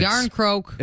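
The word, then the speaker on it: Järnkrok: And Yarncroak, and it starts spelled with a J, yep. So that's even better.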